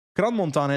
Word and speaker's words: Crans-Montana: Crans-Montana is actually the pronunciation, apparently. – Yeah, apparently. Why are they putting S in it? – Exactly.